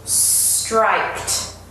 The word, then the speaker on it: striped: Careful with the pronunciation here. It's not strict. Okay, I love that a lot. And it's not strict either. It is striped.